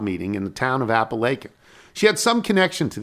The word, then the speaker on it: Apalachin: So in reference to the mob meeting in the town of Apalachin, it's commonly pronounced like Apalachin. Apalachin.